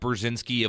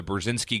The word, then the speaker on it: Burzynski: Again, this is Brett Burzynski of the Burzynski Group.